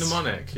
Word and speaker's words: mnemonics: Mnemonics. – Mnemonic, yeah.